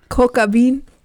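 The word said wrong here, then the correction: coq au vin: Coco Van. – What? That is not how it's spelled. – No, not at all. – Coco Van. You can thank the French.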